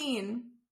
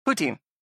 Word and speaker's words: poutine: Poutine and cream making you so nervous. – You're like doubling up on the first vowel. So it sounds poo.